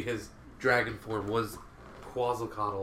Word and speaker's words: Quetzalcoatl: It's Quetzalcoatl. So yeah, like koala, but Quetzal. Okay, so Quetzalcoatl.